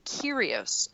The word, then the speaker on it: Kyrgios: Assuming Nick pronounces it traditionally, that G is silent. It should be Kyrgios and Euro.